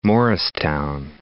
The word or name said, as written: Morristown